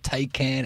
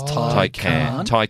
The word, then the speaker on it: Taycan: It's not Taycan. It's not Taycan. It's Taycan.